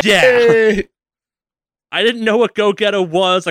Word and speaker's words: Gogeta: Gogeta. I want to pronounce it Go. I pronounce it Gogeta. for like, so. for a fucking decade. Gogeta is so much cooler than Gogeta.